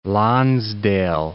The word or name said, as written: Lonsdale